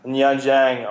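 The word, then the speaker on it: Nha Trang: Bis man erstmal drauf kommt, dass dieses, zum Beispiel gibt es ja dieses, schreibt man Na Trang, diese Stadt. – Ja. Warst du da auch? Ja, da war ich auch. Ja, und die spricht, spricht man ja Nianjiang aus.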